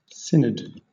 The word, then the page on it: synod: British English